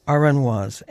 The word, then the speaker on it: Aranjuez: I'm going to look at my pronunciation note again. It's Aranjuez, and that's a town in Portugal, I think it said.